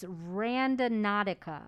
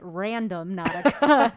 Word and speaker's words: Randonautica: The name of the app is I had said it wrong. It's Randonautica. – Yeah. – I was calling it random Nautica because that's what I thought it was like.